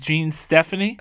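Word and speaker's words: Jean-Stéphane: John Stephane Suvar, is that how I say it? – I will allow that pronunciation. I believe it's Jean Stephanie, but I will allow how you said it.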